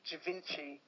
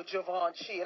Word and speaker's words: Givenchy: It's only the other day I learned how to pronounce Givenchy. I used to pronounce that word Givenchy. It wasn't until one day Madonna was at some award ceremony. You know, they always ask her, who are you wearing? – Yeah. – And she said, oh, Givenchy. And I thought, that's how you pronounce it.